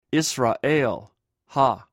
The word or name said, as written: israhl